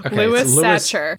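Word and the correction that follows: Sachar: It should be pronounced Sacker, like someone who tackles quarterbacks, or someone who stuffs potatoes into sacks.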